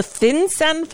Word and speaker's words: FinCEN: The FinCEN files? Is that how it's pronounced? The what? – Yes, you've pronounced it correctly.